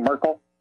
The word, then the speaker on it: Merkel: And we discovered in our research on this that the current German Chancellor, Angela Merkel, is that how you pronounce her name, Merkel? – That's correct.